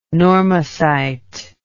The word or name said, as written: normocyte